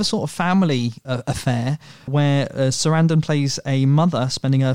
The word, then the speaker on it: Sarandon: Sarandon. Apparently, I get laughed at when I go on American podcasts, and they go, "Oh, that's not how you say it." but apparently, it's supposed to rhyme with abandon.